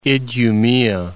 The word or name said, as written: Idumea